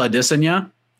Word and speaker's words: Adesanya: Someone hit me up on Twitter because I said Israel Adesanya, which is the correct pronunciation. – That's right. That's what he wants. How he wants it.